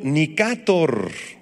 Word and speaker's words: Nicátor: El tercero de los generales de Alejandro se llamó Seleuco Nicátor, con acento en la A.